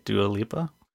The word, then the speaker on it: Dua Lipa: So this is Tim Cook's interview with, am I saying it right? Dua Lipa? – You are saying it right.